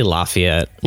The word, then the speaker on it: Lafayette: which not all of our podcasts are live, but we are here in Lafayette. Am I saying that right? Lafayette? – Yeah, depending on where you're from. – How do you say it? – I say Lafayette.